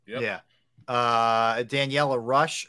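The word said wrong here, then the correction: Ruah: or Rua, excuse me, is 39 years old.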